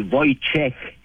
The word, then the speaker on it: Wojciech: Chez nous, on dit Wojtek, qui s'écrit W-O-J-T-E-K, comme le W se prononce par V, mais chez nous, c'est Wojciech. Puis, ça se finit en CH, comme les Canadiens.